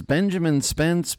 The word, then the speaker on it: Benjamin Spence: And joining us is Benjamin Spence.